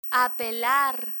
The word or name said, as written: apelar